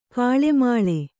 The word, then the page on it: ಗಾಳುಮೇಳು: gāḷu mēḷu